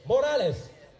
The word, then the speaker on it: Morales: That's how you say it. Morales. I mean, what do you say? Call it tortilla? Really? Ain't you dang quesadilla? No. It's Morales.